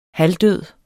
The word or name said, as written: halvdød